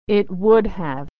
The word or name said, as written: it would have